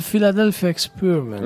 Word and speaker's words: Philadelphia Experiment: Tutto questo è passato alla storia come il Philadelphia Experiment, o come si pronuncia, io non lo so. – Il Philadelphia Experiment. – Experiment, certo.